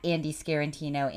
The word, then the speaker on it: Scarantino: I have changed my handle recently at Andy Scarantino. Andy has two E's, A-N-D-E-E-S-C-A-R-A-N-T-I-N-O. I know, four syllable last name. Really, really big stuff, but at Andy Scarantino, you can find me there.